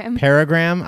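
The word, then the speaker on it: Peregrym: Well, I'm just going to go with Missy Pelligram. Is that how you say her name? – Peregram.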